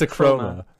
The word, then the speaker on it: Akroma: It's Akroma. – The A is silent.